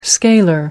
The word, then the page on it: scalar: American English